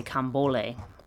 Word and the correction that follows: Candomblé: but I don't remember if I'm saying if I'm missing a um, what you call it. Um, uh, what is it? Uh, candombole. Sorry, I knew I was I knew I was missing a syllable somewhere. Candombole.